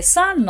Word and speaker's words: son: はい、えー、 son の太陽と息子の s ん、 n これも同じ発音になるんですね。